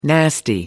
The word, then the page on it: nasty: American English